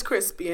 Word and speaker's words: Chrispy: And my name is Crispy, and that's spelled C-H-R-I-S-P-Y.